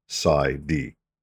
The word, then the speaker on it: PsyD: So that's abbreviated as capital P, lowercase s, lowercase y, capital D, and pronounced as PsyD.